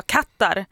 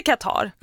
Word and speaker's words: Katar: Så det var ju liksom, alla på Sveriges Radio gick runt och sa kattar. När det, det heter Katar.